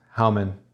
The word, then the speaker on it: Hauman: Hauman is the correct pronunciation.